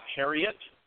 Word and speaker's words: Herriott: So, with us today is Mr. Scott Herriott.